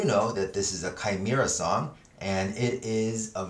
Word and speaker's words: Chimaira: Chimaira uh, it's spelled C H I M A I R A, and it's off of their self titled album Chimaira.